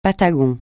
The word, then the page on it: Patagon: francais